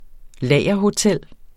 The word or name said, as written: lagerhotel